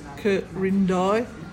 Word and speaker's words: Quirindi: Corindai is spelled Q-U-I-R-I-N-D-I, and it's pronounced Corindai, and it means nest in the hills.